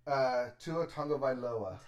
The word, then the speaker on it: Tagovailoa: Tua Tagovailoa.